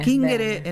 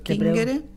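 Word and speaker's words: cingere: Kingere en el latín clásico y chingere, pero se escribe singere con singere.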